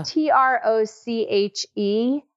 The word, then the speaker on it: troche: Troche. It's oh. T R O C H E. Okay. The French pronunciation is troche, but Americans oh. call it a troche, which is uh, it, yeah.